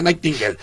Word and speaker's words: Nightingale: Hay una cantante que se llama Maxine Nightingale, por eso puedo pronunciar bien este nombre de Florencia Nightingale.